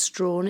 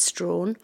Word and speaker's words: Strachan: It's spelt Strachan, S-T-R-A-C-H-A-N, but it's definitely pronounced Strawn. It's a Scottish surname. My father was always very insistent that we pronounced it Strawn, and it's basically because our family are from north of the Tay.